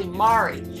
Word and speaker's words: Mari: And I'm one of these days, Pete, you're actually going to pronounce my name correctly. It's actually Mari. So Mari. Maybe Mary and Mary Ann can pronounce Mari better.